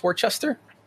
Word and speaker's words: Worcester: But what's how do you pronounce Worcester? – Worcester. That's how you say it with a Boston accent. If you wanted to say it in its proper English accent, you'd say Worcester.